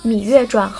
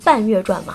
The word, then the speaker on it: 芈月传: 任何脱离当今思想内核的剧都不会得到受众的欢迎，比如说最近大热的《芈月传》，好吧，真的没有人像我一样第一次看到这个名字读成《半月传》吗？